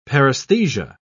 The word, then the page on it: paresthesia: British English